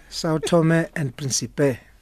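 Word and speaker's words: São Tomé e Príncipe: Maybe coach, am I saying this correctly, this name? – You can't say it better than any person. – How about that? – Sao Tome and Principe.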